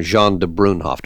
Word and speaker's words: Jean de Brunhoff: Give me a couple of minutes here, a capsule history of Babar and Jean de Brunhoff. Do I say that correctly? – You say that perfectly correctly.